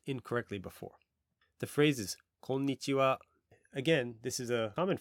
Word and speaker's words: konnichiwa: In terms of the pronunciation, notice I'm raising my intonation and keeping it high at the end. Konnichiwa. Konnichiwa.